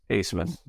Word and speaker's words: Abmas: Asemus. – Asemus? Isn't it A-B-M-A-S? – The B is silent.